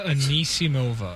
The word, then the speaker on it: Anisimova: Amanda Anisimova.